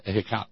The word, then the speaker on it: Ehecatl: I don't think you actually pronounce the L so strong. Ehecatl.